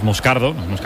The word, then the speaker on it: Moscardo: Entonces, sí, como bien ha pronunciado Unai, es Moscardo, es brasileño, sí.